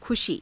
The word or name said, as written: khushii